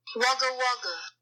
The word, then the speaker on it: Wagga Wagga: Like W-A-G-G-A, W-A-G-G-A. – Siri, how do you pronounce W-A-G-G-A, W-A-G-G-A? – Here's what I found on the web for Siri. How do you pronounce Wagga Wagga? – Wagga Wagga.